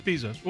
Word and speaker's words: Bezos: Am I saying his name right? Is it Bezos or Bezos? – Yep. It's Bezos.